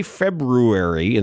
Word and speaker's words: February: I do say February instead of February. – February? – February. There's an R.